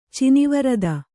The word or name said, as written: ಚಿನವರದ